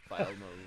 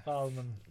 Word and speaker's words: Philemon: Philemon. – Yes. – Philemon.